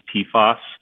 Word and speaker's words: PFAS: So the way people pronounce that typically is PFAS, and I can't pronounce the underlying chemical name that stands for, so don't feel bad about that.